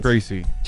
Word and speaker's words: Tracy: I'm Tracy. – And my name's Tobias. – Tobias. Tobias and Chasey. – Love the accents. – Tracy. – Sounds like Chasey.